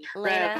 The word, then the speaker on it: Lena: It's Elena. It's it's like Elena without the e. – Lena.